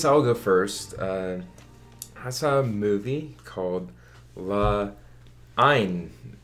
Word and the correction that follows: La Haine: La Haine.